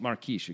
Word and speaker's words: Marques: Also, you know, you could just be like, I mean, I guess you're calling a shove against Pedro Marquez, I assume, no matter Marquise. – What. Marquise, excuse me. I spelled Marquez. I mean, no, it's spelled Marquise and Marquez. It's confusing. Anyway.